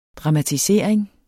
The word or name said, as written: dramatisering